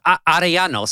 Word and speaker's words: Arellano: So, Arellano. So I always tell people that the double L is a Y. Just think of it that way.